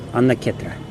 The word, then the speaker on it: Анна-кшетра: А Джаганатхапури, другое название этого места, Анна-кшетра. Анна-кетра. Кшетра они произносят как кетра.